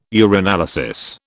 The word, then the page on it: urinalysis: British English